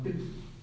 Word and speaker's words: Pilsen: Se pronuncia como Pilsen.